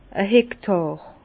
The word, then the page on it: e Hektàr: Bas-Rhin; Herrlisheim